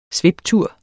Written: sviptur

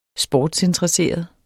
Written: sportsinteresseret

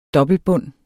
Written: dobbeltbund

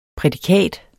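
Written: prædikat

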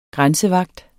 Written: grænsevagt

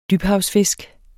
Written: dybhavsfisk

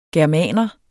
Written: germaner

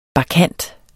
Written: bakkant

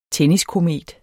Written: tenniskomet

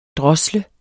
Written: drosle

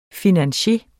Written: FINANCIERERNES

